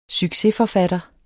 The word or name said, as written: succesforfatter